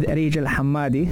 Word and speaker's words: Areej Al Hammadi: And coming up next, we're talking about taking it to the next level with Areej Al Hammadi. Did I say it right? – Yeah.